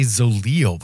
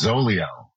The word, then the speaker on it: Zoleo: You know, what's funny is I always say Zoleo, but I'm not really saying it right, am I? – It's Zoleo. – Zoleo. Zoleo, yeah. Not Zoleo.